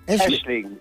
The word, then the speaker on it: Aisling: Aisling. Aisling. Ja, A-I-S en dan Ling. – Aisling. – Ja, dat is een Gaelic woord. En dat wordt altijd net even anders uitgesproken dan hoe je het schrijft.